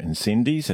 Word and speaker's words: Incendies: The first is called Ensondi, or Incendies, as we like to pronounce it down here,